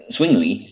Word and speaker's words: Zwingli: Some of the Swiss reformers were Ulrich Zwingli. – Yeah, the Z is pronounced like a TS. Zwingli, yeah.